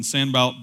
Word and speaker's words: Sanballat: Like when Sanballat the Horonite, and that is correct pronunciation,